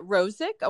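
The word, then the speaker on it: Rosic: Is it Rosick? I want to make sure I say it right. – Yeah, that's how awesome. – You say it. – Awesome. And that's R-O-S-I-C is her last name.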